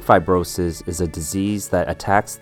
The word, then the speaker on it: fibrosis: Next up, we have fibrosis. Three syllables. Phi has a long I sound, phi. Bro sounds like what you'd call a good guy friend. Hey, bro. Sis is exactly how it sounds. Sis, like a brother and sis. Fibrosis.